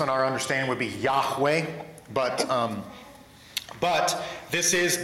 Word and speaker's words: Yahweh: And so the pronunciation of it, to my understanding, most Hebrew scholars will assert that the pronunciation is Yahweh, but there are others that put another vowel in between the H and the W to where it's three syllables, and that's where you get like the English King James translation as Jehovah.